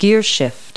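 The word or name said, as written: gearshift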